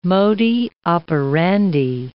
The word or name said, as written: modi operandi